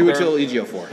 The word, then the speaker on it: Chiwetel Ejiofor: So, I can't say his name. Chiwetel Ejiofor? The guy who played Chiwetel Ejiofor. – Thank you.